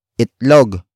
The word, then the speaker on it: itlog: It is an object marker that introduces what Jack is cooking, and that is itlog.